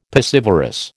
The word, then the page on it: piscivorous: American English